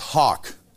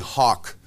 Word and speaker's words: hough: Is that word H-O-U-G-H is pronounced hawk.